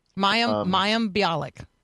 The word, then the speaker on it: Mayim Bialik: Mayim Bialik.